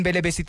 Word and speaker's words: Belebesi: Nelson Belebesi, thank you so much for joining us.